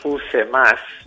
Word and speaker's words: UCMAS: Nosotros, por cuestión fonética, pronunciamos UCMAS, que suena un poquito menos seco.